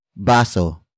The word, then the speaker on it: baso: Baso. Baso. Baso is spelled as B-A-S-O.